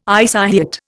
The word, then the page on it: isohyet: Indian